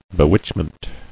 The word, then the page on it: Bewitchment: be·witch·ment